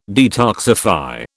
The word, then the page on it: detoxify: British English